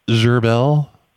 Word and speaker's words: Gerbel: Gerbel. It rhymes with DeSalle.